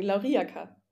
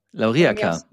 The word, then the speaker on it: Lauriaca: Lauriaca Linz. War das jetzt richtig? – Nehmen wir es mal mit. Nicht bitte? AK, sondern ohne Betonung, Lauriaca. – Lauriaca.